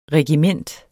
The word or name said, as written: regiment